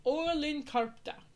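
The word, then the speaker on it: Oulun Kärpät: Signing with the, and I'm not going to pronounce this right, the Oulun Karpta. – That sounds good enough.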